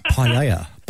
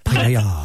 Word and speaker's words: paella: I say paella, but I believe on a technicality it's paella. – All right, well, look, that's up for debate. – Well, look, the Spanish language is very sexy language. You got to say it right. Paella. Paella.